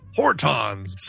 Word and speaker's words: Hortons: Hortons!